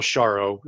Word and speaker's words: Hxaro: She documented a system among the Kung or Zhuntuanzi, sometimes also called Bushmen in southwestern Africa, Namibia, Botswana, that they call Sharo. It's spelled H-X-A-R-O.